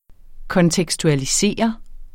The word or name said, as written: kontekstualiserende